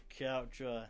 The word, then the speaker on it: Couch: If I introduce myself to most everybody, I say my name's Josh Couch, like you sit on.